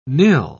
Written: nill

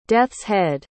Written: death's-head

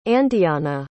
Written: andeana

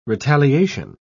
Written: retaliation

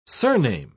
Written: surname

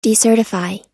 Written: decertify

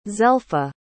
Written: zelpha

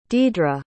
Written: diedre

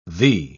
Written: thee

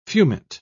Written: fumet

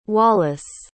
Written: wallis

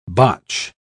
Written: botch

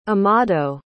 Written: amado

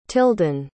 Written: tilden

